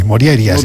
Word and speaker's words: Morjaria: Bueno, la J normalmente se pronuncia como I, Morjaria.